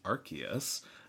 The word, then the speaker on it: Arceus: They also announced Pokemon Legends Arceus, which they said out loud, so I'm pretty sure that's the pronunciation of it.